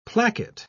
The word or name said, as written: placket